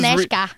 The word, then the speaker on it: Anezka: I said, so it's Aneska. – I was trying to read it by verbatim how it was spelled, which is A-N-E-Z-K-A. So it's like Anska.